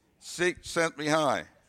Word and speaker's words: Csikszentmihalyi: I've been saying it wrong all this time. – I love his book, too. – Okay, you love his book, too. And you can actually pronounce his name. Okay. Okay. Say it again. Mihai Chick sent me high. Cheek sent me high. The cheek sent me high. I got it now.